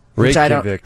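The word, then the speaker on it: Reykjavík: Reykjavik. – Yeah, thank you. I knew you'd know because Steve Bertrand traveled. – We've been there. No J's. Don't pronounce the J's.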